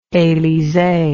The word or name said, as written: elysee